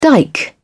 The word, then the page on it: dike: British English